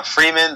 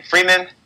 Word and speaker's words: Freeman: My Facebook and Instagram, the same exact thing is Sean Freeman. That is spelled S-E-A-N, Sean. Last name Freeman, F-R-E-E-M-A-N.